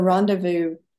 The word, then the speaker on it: rendezvous: And it seems fitting that your new book is titled Rocky Mountain Rendezvous. Am I saying it properly? Rendezvous? – The way Americans pronounce it would be rendezvous. That S is silent.